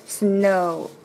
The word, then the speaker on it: snow: snow o，而且 snow 这里会有个鼻音在这里，no no no no no no，yes yes yes，很像的发音。